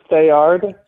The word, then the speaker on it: Theard: Did I pronounce that right, the last name? – You're close. It's Thayard.